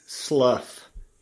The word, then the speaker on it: Slough: Well, not if they've met your grandfather, my father, who says slough rhymes with plow.